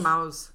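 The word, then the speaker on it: Maus: And for those that have been keeping track in the media, East Tennessee, the McKinn County School District, banned Mao, or how do you pronounce that? – I think Mouse? It's Mouse.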